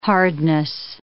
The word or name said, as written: hardness